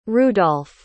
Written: rudolf

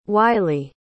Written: wylie

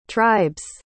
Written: tribe's

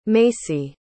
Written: Macy

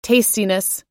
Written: tastiness

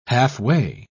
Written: halfway

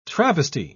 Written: travesty

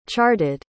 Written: charted

